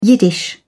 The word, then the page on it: yiddish: British English